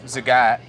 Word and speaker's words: Zagat: So Zagat, which I didn't know that's how you pronounced it until I got nominated for this, but they do a 30 under 30 in the city, and I was really lucky enough, I mean humbly lucky enough.